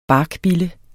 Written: barkbille

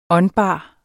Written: åndbar

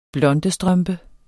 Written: blondestrømpe

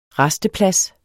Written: rasteplads

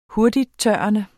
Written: hurtigttørrende

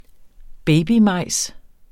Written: babymajs

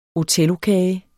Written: othellokage

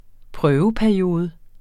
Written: prøveperiode

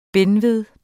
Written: benved

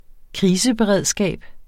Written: kriseberedskab

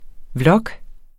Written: vlog

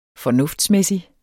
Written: fornuftsmæssig

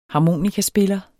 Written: harmonikaspiller

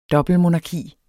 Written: dobbeltmonarki

